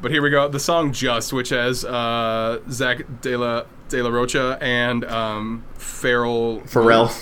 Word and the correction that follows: De La Rocha: I like that you mispronounce both of their names. – I don't know. – Music podcast ever. – De La Rocha.